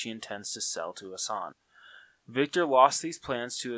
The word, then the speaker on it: Hassan: Hassan, a Middle East terrorist. That is how you pronounce his name, right? Hassan? – Yes.